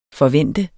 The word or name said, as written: FORVENTEDE